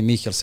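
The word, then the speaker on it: Michels: Taj fudbal koji on igra sada, koji forsira, to je prvo krenuo čuveni Mihels ili Mišels, kako ga ko izgovara, posle je to napredio Krojfa, sada je Guardiola odneo na to još na neki veći nivo.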